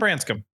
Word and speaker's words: Branscum: Ben Branscum.